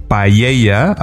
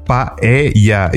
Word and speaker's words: paella: And there's a funny thing because everyone out of Spain says paella, and it's not like that. You are pronouncing it like it has like a double L. I mean, it's paella. It's P A E-L-L-A.